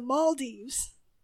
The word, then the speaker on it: Maldives: Maldives. Crap, I pronounced it wrong. It's the Maldives. I even spelled it out phonetically.